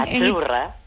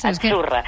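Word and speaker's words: azzurra: Sí, como porque pizza, la Z no es la Z como decimos en catalán tan sonora, no. Ah, es azurra. – Muy bonito, muy bonito. – Es como pizza.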